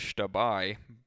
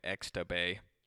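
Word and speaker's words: Xtabay: and some unnamed male vocalist playing a song that should be pronounced Shtabai, but they're calling it Extabay.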